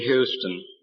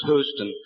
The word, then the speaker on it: Houston: It's a very great pleasure to me indeed to be in Houston and to learn how to pronounce the name of your town. I always thought it was Houston,